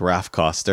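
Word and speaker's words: Raph Koster: I did look up how to pronounce his name. It's Raf Koster.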